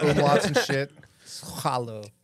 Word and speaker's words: Soileau: And Soileau. – Soileau. Yeah. But it's spelled different. – It's spelled weird. – It's S-W-A-A-A-A-L.